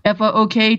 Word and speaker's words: Fook: F O O K T H I S. I'm sure you all pronounce it um, Fook in your head, but it's Fook. Um, also, the same handle on Twitter.